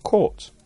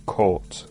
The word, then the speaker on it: caught: Caught. Ojo, be careful of the pronunciation of caught. C-A-U-G-H-T.